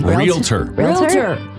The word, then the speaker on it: Realtor: Well, now, you told me the other day that on air, I actually said, realtor, how do you say it properly? – Realtor. There's no A there.